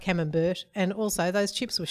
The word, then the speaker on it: camembert: A lot of people, there was a lot of talk about how I pronounce camembert. Apparently that's oh. – wrong. – Yeah, I did. Tom Peterson, friend of the show, actually picked me up on that and said, why on earth did you let get away with saying camembert? And I said, well, you know, we're not in France. He can say it however he wants. – How do you say it? – Camembert. – Oh, of course. Camembert, not camembert. Camembert.